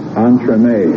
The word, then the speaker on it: entremets: M-A-L-E-F-I-C-E-N-C-E. – There you are. Beautiful. Look at that. That's a relief. – This is a French word. The singular pronunciation is entremets. The plural pronunciation is entremets. It's a dish is added to the main course of a meal, like a side dish of vegetables or something like that. Now, both the singular and the plural spell the same. – Entremets. This word is spelled E-N-T-R-E-M-E-T-S. Entremets. – Entremets. Or entremets. – E-N-T-R.